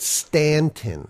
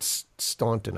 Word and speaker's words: Staunton: Well, no, because if you read on, it says here in Staunton, they pronounce it Stanton.